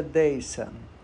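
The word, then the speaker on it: Dasein: se pronuncia deisa deisa que significa é, uma junção da palavra da, né, que dá o som de dei, né, que significa i, e san, que significa ser ou estar em alemão.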